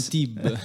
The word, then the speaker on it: Antibes: Precisamente ad Antibes. – Antib, Antibes. Antib, Antibes, Antibes. Mi raccomando, Antib, pronunciare Antib. – la S alla fine.